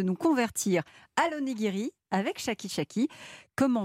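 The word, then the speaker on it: Shaki Shaki: Vous avez 38 ans, Alexandre, et vous êtes le cofondateur de Chaki Chaki. Alors, ça s'écrit S-H-A-K-I et plus loin S-H-A-K-I.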